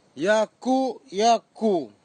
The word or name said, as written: iakuiaku